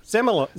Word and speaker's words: Zelmerlöw: Måns, now how do you say it? – Zelmerlöw? Zelmerlöw.